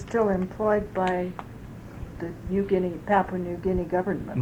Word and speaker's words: Niugini: New Guinea Mining. – That's spelled N-I-U... – N-I-U-G-I-N-I. That's the pidgin spelling for New Guinea.